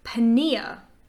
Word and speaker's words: Peninnah: But his other wife, Peninnah.